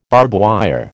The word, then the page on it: barbwire: American English